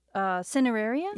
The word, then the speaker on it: Cineraria: Cineraria? – Yeah.